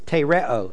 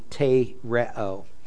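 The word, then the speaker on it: tereo (τηρέω): And the Greek is tereo, T with an eta, R-E-O, long E, tereo.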